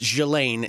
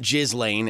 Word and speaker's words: Ghislaine: I think it's Ghislaine. – It's pronounced Ghislaine. It's not Ghislaine, as a lot of people think. – That's what it looks like, Ghislaine.